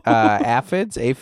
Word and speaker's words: aphids: They were giant aphids? How do you say that? Aphids. – Yeah.